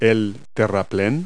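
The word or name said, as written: embankment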